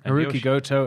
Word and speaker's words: Hiroki Goto: Hiroki Goto. Am I saying that right? – Hiroki Goto and Yoshihashi.